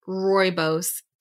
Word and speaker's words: rooibos: It's a cocoa chai rooibos tea. And I did Google how to pronounce that, and I know I'm pronouncing it correctly.